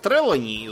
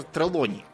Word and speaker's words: Трелони: Вот, например, Сквайр э, Трелони из «Острова сокровищ», он на самом деле Сквайр Трелони. Просто у нас почему-то была сто лет назад такая идея, что все фамилии в Англии имеют ударение на первый слог. Поэтому ага. – Трелони. – Хотя Трелони это как бы... Э, потому что там слово лон, то есть лук в середине.